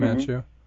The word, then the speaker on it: Manchu: Is that how you pronounce that, Manchu? – Manchu, yep.